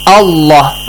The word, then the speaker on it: Allah: So we said if there is a fatha, I mean any letter with fatha, and then the word Allah comes, the word Allah must be pronounced heavily. Allah, understood Mahdi? – But mim don't have any.